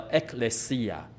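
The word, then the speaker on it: Ekklesia (ἐκκλησία): 新约希腊文的教会的发音是这个样子，叫 Ekklesia，它是两个字合成的。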